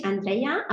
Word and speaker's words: Andreia: Okay, also ich bin Andrea, eigentlich Andrea, aber das ist ein portugiesischer Name, den viele nicht aussprechen können in Deutschland.